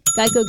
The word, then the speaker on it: Geico: Geico Gecko. – That's correct. Hey. The original idea was that a gecko could help people remember the name Geico, which often got pronounced like gecko.